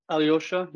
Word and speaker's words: Aljoscha: And though there's a J in your name, it's not pronounced. So it's Aljoscha? – Aljoscha, yeah.